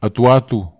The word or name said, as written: atuatu